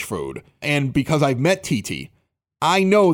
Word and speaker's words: Thithi: Now, it looks like Thithy. And the only reason I'm telling you this is because when you go to the website later, you have to spell it T-H-I-T-H-I.